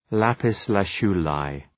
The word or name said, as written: lapis-lazuli